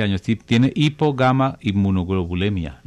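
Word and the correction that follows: hipogammaglobulinemia: Lo dije bien, ¿no es cierto? – Sí. Una hipogamma globulinemia.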